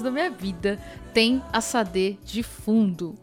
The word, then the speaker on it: Sade: Gente, o certo é Sade.